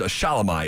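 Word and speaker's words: Shelomi: And excuse me, her name's Shalomi is how you pronounce it.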